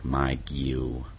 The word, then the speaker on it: mageuo: And the Greek word is M-A-G-E-U-O. And the pronunciation looks like magio, magio, magio.